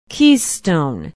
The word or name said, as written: keystone